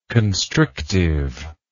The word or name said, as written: constrictive